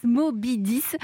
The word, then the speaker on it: Mobidys: Alors Mobidis, pour nos auditeurs, ça s'écrit M-O-B-I-D-Y-S, Mobidis.